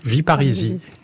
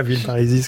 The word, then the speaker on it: Villeparisis: Je crois, et le narrateur le précise, qu'on dit « Villeparisis » et pas « Villeparisis » comme le nom s'écrit.